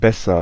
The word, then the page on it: meilleur: [mɛ.jœʁ]; /me.jœʁ/